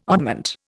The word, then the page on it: oddment: Indian